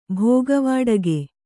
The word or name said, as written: ಭೋಗವಾಡಗೆ